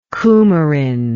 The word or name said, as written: cumarin